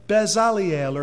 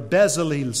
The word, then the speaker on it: Bezalel: And it says that the Lord spake unto Moses in Exodus 31:1, "See, I have called by name Bezaliel," or Bezalel, some pronounce it,